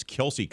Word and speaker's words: Kelce: Words that end in CE where you don't pronounce the E. It's a little bit of education here, courtesy of Aaron with our Travis Kelce conversation from earlier. Ice, mice, twice, thrice, pace, face, race, place, grace, just to name a few.